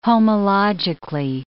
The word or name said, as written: homologically